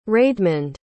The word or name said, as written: raedmund